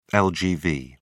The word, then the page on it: lgv: British English